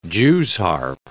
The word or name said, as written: Jews' harp